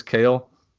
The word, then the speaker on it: Cael: So Cale Baker, C-A-E-L, that's how I'm pronouncing it. I think it's Cale.